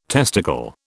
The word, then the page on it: testicle: British English